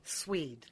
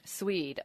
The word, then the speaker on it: Szwed: Szwed. Szwed.